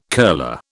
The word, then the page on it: curler: British English